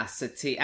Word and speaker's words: Satie: So Admiral Satik, is that how you pronounce it? – Yeah, Satik.